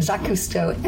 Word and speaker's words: Jacques Cousteau: And it had come at the request of the Jacques Cousteau, I gotta say it right, Jacques Cousteau Estuary Reserve.